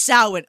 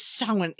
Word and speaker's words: Samhain: It is pronounced Samhain. Okay, it's spelled Samhain.